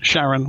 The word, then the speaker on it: Charon: Charon? – Charon.